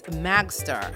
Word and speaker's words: Magzter: Okay, last one on my list is Magster, and that is spelled M-A-G-Z-T-E-R. That again is M-A-G, you could say Z-T-E-R, all right.